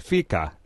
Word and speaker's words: fika: What is this, and are we saying it right? Fika, F-I-K-A. – Yeah, you've got your Swedish down pat.